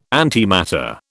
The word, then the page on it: antimatter: British English